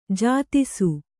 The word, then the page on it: ಜಾತಿಸು: jātisu